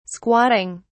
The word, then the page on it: squatting: British English